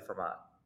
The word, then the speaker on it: Fermat: And I don't know what the proper pronunciation is, but I say Fermat. Like I use a little T at the end, not a big T, but I say Fermat. – Mm-hmm. Yeah. So would you say that Fermat is akin to tomato, tomato, potato, potato? – I think it's actually highly akin.